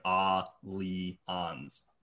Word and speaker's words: Allianz: They had a whole ad campaign that was, their slogan was just, here's how you pronounce our name. It was like, Ah-Lee-Ons, which is really sad.